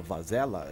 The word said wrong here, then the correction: Varela: ele falou que. – Varela. Tem... Drauzio. – Isso, Braulio Varela.